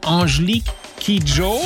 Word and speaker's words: Angelique Kidjo: Klingt einfach, je nachdem, wie man nuschelt, so ähnlich. Angelique Kid Joe.